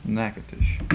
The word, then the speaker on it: Natchitoches: Natchitoches. Natchitoches. Indian name. – Is that ever pronounced any other way? – No.